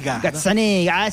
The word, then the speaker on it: Gazzaniga: El trofeo fue diseñado por el escultor italiano Silvio Gazzaniga. A ver si lo dije bien. – Gazzaniga.